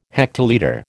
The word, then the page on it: hectolitre: American English